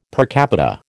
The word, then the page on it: per capita: American English